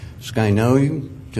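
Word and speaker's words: skinoi (σκηνόω): This word, according to Strong's, is pronounced skinoi,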